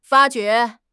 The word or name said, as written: 发觉